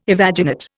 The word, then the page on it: evaginate: Indian